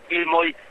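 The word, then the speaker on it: Filmoj: Quizás haya que precisar que Filmoy se escribe con una J al final, La J en Esperanto se pronuncia como una Y,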